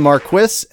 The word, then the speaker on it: Marquis: And that was The Marquis, as written by Meg Ellison. That's how they say it in England.